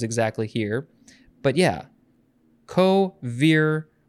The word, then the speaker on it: vir: So it is Latin, perhaps from co plus vir, which is spelled V-I-R. It looks like vir, but they would have pronounced it vir.